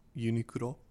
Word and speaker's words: Uniqlo: How do you pronounce that? – Uniqlo, technically. – Uniqlo. – Yeah, we always, all of the American people in Japan just called it Uniqlo.